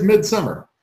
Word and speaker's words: Midsommar: It is Midsommar.